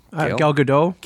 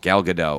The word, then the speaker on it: Gal Gadot: Gal or Gale? – Gal Gadot. – Gal Gadot.